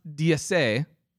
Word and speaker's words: DS: Pronounced DSA in French for DS, it also is the same pronunciation or sounds like the term goddess in French.